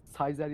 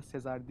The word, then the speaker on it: Caesar: Ee, Latince'de C'ler yazılan bütün C'ler istisnasız e, K diye okunur. Hani Cicero diye okuyan var, Ciceron diye okuyan var. Ondan sonra ilginç ilginç şeyler var. – Anladım. – E, mesela Kaiser, o yüzden Kaiser ya da Sezar değil, Kaiser olarak ya okunuyor.